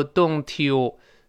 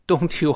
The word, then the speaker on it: Don't you: 碰到了 u 前面那个 e，一定变成 ch 这个声音，所以不要读 don't you Don't you 好难受，那我们听着就别扭了啊！